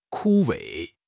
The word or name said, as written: 枯萎